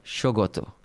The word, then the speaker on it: Saugata: So how do you pronounce that word? – Shogoto.